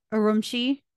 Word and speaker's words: Urumqi: Urumqi.